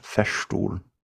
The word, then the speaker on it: Festool: I believe it's pronounced Festool. – Festool.